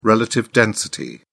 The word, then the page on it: relative density: British English